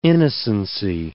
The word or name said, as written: innocency